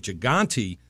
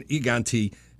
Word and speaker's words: Gigante: This was a race where we were going to see if Gigante was, or the Spanish pronunciation, Gigante, was going to sweep Colonial's Twin Turf for three-year-olds, and didn't get a whole lot of respect.